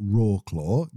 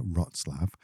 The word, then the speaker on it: Wrocław: Wrocław is spelled, to us Brits, is spelled Rawclaw, Wrocław. But it's pronounced, as we found out when we got there, Wrocław.